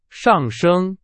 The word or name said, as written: 上升